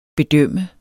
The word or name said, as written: bedømme